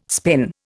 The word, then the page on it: spin: Indian